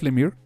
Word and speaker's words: Lemire: ya supe, es Jeff Lemire, así se pronuncia, ya.